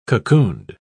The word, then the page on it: cocooned: British English